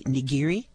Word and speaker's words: nigiri: There's, am I pronouncing correctly, nigiri? – Nigiri is a verb. It means to grip or to hold something.